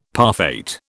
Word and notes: parfait: British English

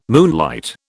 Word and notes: moonlight: British English

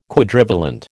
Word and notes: Quadrivalent: American English

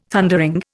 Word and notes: Thundering: Indian

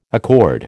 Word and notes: accord: American English

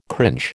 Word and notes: cringe: American English

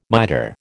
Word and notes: miter: American English

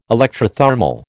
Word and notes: electrothermal: American English

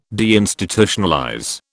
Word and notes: deinstitutionalize: British English

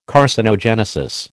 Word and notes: carcinogenesis: American English

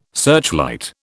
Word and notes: searchlight: British English

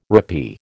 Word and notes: rappee: American English

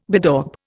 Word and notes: bedaub: Indian

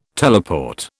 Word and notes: teleport: British English

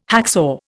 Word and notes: hacksaw: Indian

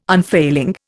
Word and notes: unfailing: Indian

